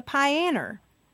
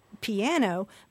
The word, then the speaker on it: piano: people also talk about playing the pianer. I mean, my dad used to go tell me to go practice the pianer, and he was making a joke because he knows that the standard pronunciation is piano, but he was imitating the language of his parents.